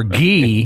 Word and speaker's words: Gee: or Gee, because it's G-E-E.